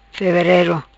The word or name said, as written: Feverero